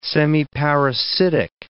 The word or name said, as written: semiparasitic